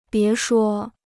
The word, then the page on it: 别说: bié shuō